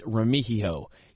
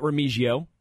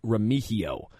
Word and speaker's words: Remigio: Nate Taylor of The Athletic reported that rookie Nico Remigio, and yes, it's Remigio. He tweeted it out. It's not Remigio. Like, it looks like it would be pronounced. It's Remigio.